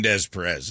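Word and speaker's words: Des Peres: You mean Des Perez? That's how it's pronounced.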